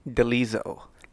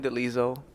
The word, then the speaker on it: Delizo: Delizo. – Delizo. – I didn't know. You know what? Your middle name's Felix? – Middle name's Felix. Last name Delizo.